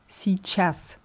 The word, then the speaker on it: сейчас: Und bei der Aussprache passiert hier sowas ähnliches wie bei dem Wort сейчас. Сейчас heißt jetzt. – Ja, und es wird in der Umgangssprache oft als сейчас ausgesprochen. Nicht zu verwechseln mit dem Wort час für Stunde oder Uhr. Hier wird ch deutlich ausgesprochen.